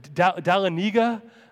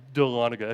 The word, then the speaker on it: Dahlonega: I was literally on the phone this week with someone, and they said, how do you say, what was it, Dahlonega? I'm like, no, don't ever say that again. Dahlonega.